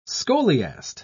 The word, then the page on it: scholiast: British English